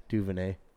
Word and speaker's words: Duvernay: I said it right. – Devin, who gives a shit? Duvernay.